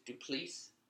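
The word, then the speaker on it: du Plessis: Darren Till versus Driscus Duplessis. Okay. – Yeah. Yeah, it's weird. I think the last S is silent.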